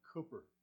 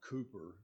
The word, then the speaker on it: Cooper: Cooper, Texas. Not Cooper, but Cooper. And they would straighten you out on that very quick if you didn't say it right.